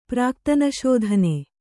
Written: ಪ್ರಾಕ್ತನಶೋಧನೆ

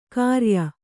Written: ಖರೆಯ

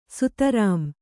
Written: ಸ್ಥಿರಂ

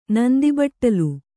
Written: ನಂದಿಬಟ್ಟಲು

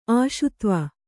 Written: ಆಶುತ್ವ

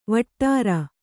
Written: ವಟ್ಟಾರ

